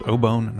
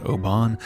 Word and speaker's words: obon: It turns out we pronounced the Japanese word obon incorrectly. The entire episode is pronounced obon, not obon.